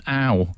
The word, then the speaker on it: Ow: Just wanted to check, my chiropractor is from Hong Kong and is called Brendan Ow. – I think that's pronounced O-W as in a shortened version of ouch.